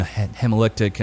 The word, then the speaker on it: hemolytic: He has this weird hypolytic, some kind of, I can't say it right, but it's some anemia. – Autoimmune hemolytic anemia. – Yeah, that's it.